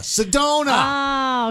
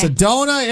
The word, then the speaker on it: Sedona: Sedona. – Oh, okay. – Sedona, Arizona. I won't forget that. It rhymes.